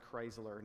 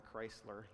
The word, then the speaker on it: Kreisler: His name was Fritz Kreisler. And he lived from 1875 to 1962. That's actually pronounced Chrysler, not Chrysler, like the car, just to clarify there.